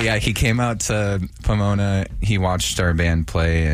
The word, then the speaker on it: Pomona: So he came out to our show in Pomona.